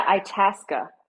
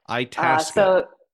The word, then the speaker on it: Itasca: Yeah, Itasca. – Itasca.